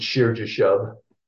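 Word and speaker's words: Shear-jashub: English readers usually pronounce it Shir Jashub, but yeah. You know, it's Sha'ar. – Yeah. – Yashuv. Yashuv.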